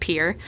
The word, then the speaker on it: Pierre: And a fun fact also, though everyone outside of South Dakota thinks the capital is Pierre, it's actually pronounced Pierre. Fun thing to know. If you ever go, you'll sound like an expert.